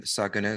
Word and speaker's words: Saguna: But in the context of that framework, you know, Hinduism talks about, and you can help me with the pronunciation, Nirguna Brahman and Saguna Brahman, right? – Yes, that's right. – Can I say that better than I did? – Nirguna and Saguna is good. Yeah.